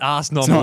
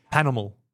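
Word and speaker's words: Pas Normal: It's not, don't not pronounce the A, you don't pronounce the S. – Correct. – Yeah. Yeah. – Yes. – So it's not Ars Normal.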